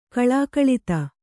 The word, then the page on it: ಕಲಾಕಲಿತ: kalākalita